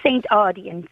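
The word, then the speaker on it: St. Audoen's: This is Saint, am I pronouncing this wrong? Is it St. Audin's School? – It's St. Audin's, yes.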